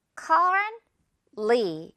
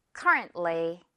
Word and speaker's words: currently: 그래서 currently 이렇게 생각을 할 거예요. currently. 중간에 T는 아주 살짝만 들리는 거죠. currently.